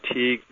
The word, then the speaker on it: Teague: The name is pronounced Teague. It sounds kind of like league, and it's spelled the same way too, just with a T on the front instead of an L.